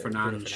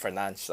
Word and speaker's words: Fernandes: Oh, I suppose oh, was maybe one position later on that was more obvious than this, but uh, Bruno Fernandez. I mean, yeah. or Fernandes. How do you pronounce it? Uh, Fernandes. Fernandes. – Fernandes. That's it.